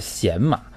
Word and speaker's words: 洗马: 对，其实这个字儿，这个字儿不能念成喜，应该是凌瑞读的很对啊，是贤马。